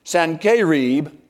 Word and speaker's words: Sennacherib: Now, when King Sennacherib, is the way we would pronounce it in the Hebrew, it actually is Sennacherib, came to Jerusalem, he offered King Hezekiah peace if the king would simply pay him tribute.